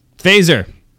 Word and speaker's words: Faesser: Survey says Andy Fazer. I think that's how you pronounce his last name. – Adams is notoriously good at pronouncing people's names. – Yes. F-A-E-S-S-E-R. – Fazer.